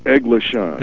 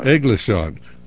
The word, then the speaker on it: Eglison: Is that the way you pronounce it? – It's pronounced Eglison. – Eglison.